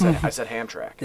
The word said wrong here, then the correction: Hamtramck: Yeah, you did. – I did, and it's Hamtramck.